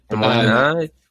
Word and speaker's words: Des Moines: You don't want to go to, I don't even know how to say it, Des Moines, Iowa? – Des Moines.